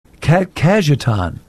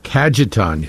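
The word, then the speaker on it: Cajetan: Today, the church celebrates the feast of Saint, and you may have to help me out on pronunciation, Kadajan? Kajetan. – Kajetan.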